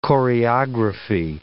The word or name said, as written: choreography